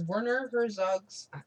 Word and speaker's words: Werner Herzog: In what year was Werner Herzog's, did I say that right? – Mm-hmm.